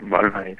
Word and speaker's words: Boal: normalmente es un nombre que cuesta, pero Boal, perfecto, sí, Boal, no hay problema.